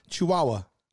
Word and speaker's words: Chihuahua: Chihuahua.